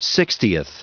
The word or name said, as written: sixtieth